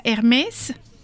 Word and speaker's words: Hermès: Ah, es, de hecho tu nombre sería Hermès, o sea, con acento, digamos, en la E, y tu apellido sería Bourdon.